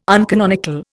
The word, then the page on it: uncanonical: Indian